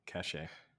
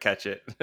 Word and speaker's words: Cachet: Cachet. All the rest are, because someone made the same mistake as you calling it Catch It.